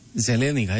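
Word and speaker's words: Zelenika: Το οποίο μεταγραφικό πλάνο του ΑΠΟΕΛ περιλαμβάνει τον κύριο Μπαράκ, τον κύριο Καρίγιο, τον κύριο Ζελένικα, έτσι προφέρεται, ναι.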